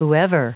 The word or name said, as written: whoever